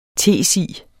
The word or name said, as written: TESI